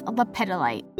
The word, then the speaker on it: lepidolite: And I was so off with the pronunciation of this. It's lepidolite.